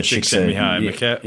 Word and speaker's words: Csikszentmihalyi: Misa Csikszentmihalyi.